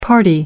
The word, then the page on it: party: American English